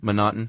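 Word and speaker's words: M'Naghten: Manotin. – It's M apostrophe Naughton, and it looks like it should be McNaughton, but they left the C out and replaced it with an apostrophe.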